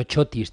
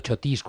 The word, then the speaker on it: Chotis: El típico, el muy madrileño Chotis, también a veces llamado Chotis con acento en la I, o al revés, ¿Es madrileño, es alemán o es escocés, como el whisky?